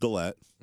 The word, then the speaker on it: Gillette: It's pronounced Gillette.